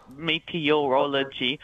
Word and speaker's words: meteorology: Is it meteorology? Meteorology. – Yes, yeah, that's right. It is like meteor.